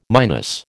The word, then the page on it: minus: American English